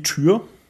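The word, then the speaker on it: Týr: Heißen die Tür? T, seltsames Y mit Strich drüber. – So habe ich sie mal ausgesprochen, halt hier der germanische Kriegsgott. – Ja, ne? – Ich habe ihm immer Tür ausgesprochen, also wie die Tür.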